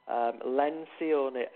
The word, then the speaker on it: Lencioni: Lencioni. – How do I spell that? – Lencioni, L-E-N-C-I-O-N.